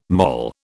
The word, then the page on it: mol: British English